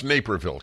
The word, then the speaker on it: Naperville: It's pronounced Naperville, correct? – Yeah.